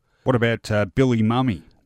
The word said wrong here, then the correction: Mumy: Bill Mumy is his correct pronunciation.